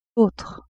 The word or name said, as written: autre